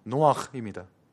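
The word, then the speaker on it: 노아흐입니다 (נח): 노아는 히브리어로 발음이 노아흐입니다.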